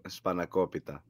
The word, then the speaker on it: spanakopita: Spanakopita.